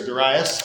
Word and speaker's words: Darius: And his father, Darius, or Darius, depending on your pronunciation persuasion, started the invasion of Greece.